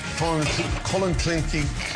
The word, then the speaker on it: Clonakilty: Irish tea bags or Clonakilty sausages from Cork. – Clonakilty.